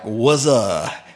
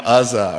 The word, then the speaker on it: Uzzah: Verse 6, when they came to the threshing floor of Nacon, Uzzah, I actually heard that was one of the pronunciations. I went online, and they were like, Wuzzah, and I thought, that's not going to work. I'm just going to call him Uzzah, all right?